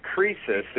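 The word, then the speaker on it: krisis (κρίσις): And the word crisis actually comes from a Greek word spelled with a K, probably pronounced krisis. It simply means decision.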